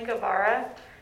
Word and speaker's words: Guevara: My name is Emily Guevara. It's G-U-E-V-A-R-A.